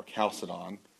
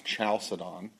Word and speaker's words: Chalcedon: And so in the wake of those controversies, you had another council, the Council of Chalcedon, or Chalcedon, sometimes pronounced Chalcedon.